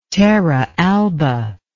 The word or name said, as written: terra alba